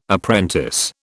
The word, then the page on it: apprentice: British English